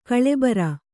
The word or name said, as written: ಗೋಲಿಬಾರು